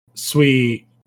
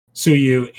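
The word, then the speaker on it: Tsuyu: Sui, is it Sui or Sue? How do you pronounce it? – Suyu into like a water area.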